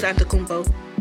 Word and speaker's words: Antetokounmpo: First of all, his first name is Giannis. – It's Giannis Antetokounmpo. – Whatever. – Antetokounmpo, yes. – His last name is A-N-T-E-T-O-K-O-U-N-P-M-P-O.